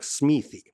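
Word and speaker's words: Smithee: Ну, то есть, э, по идее, это произносится как Смити И вот посчитали,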